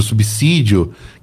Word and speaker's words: subsídio: Subsídio, subsídio, que eu nem nunca tinha me atentado. – A né? pronúncia né? correta é subsídio. – É. Muita gente fala com som de Z.